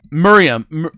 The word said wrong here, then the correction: Meruem: God, I can never say his name. The bad guy. Meruem got added.